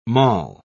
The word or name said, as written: moll